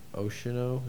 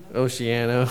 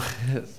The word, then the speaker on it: Oceano: Oceano. Is that how you say it? – Oceano. – Oceano. Sorry, I knew I botched that.